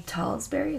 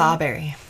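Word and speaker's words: Tsabary: Um, and so this, I love with this, and Shefali Talsberry, is that how you say Sawberry. – Sawberry. It's a silent T.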